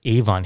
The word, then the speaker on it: Avon: See them both at the Avon Theater in West Memphis today, tomorrow, or Monday for sure. – That clip for Avon, and we pronounce it Avon here in the northeastern U.S., it was broadcast on KWEM radio in West Memphis, Arkansas in May of 1955.